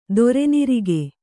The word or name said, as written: ದೊರೆನಿರಿಗೆ